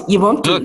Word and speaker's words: Ubuntu: And you use a word in your book, and I love this word, Ubuntu. Tell us, did I say it right? – Oh, yes, close. Ubuntu, yes.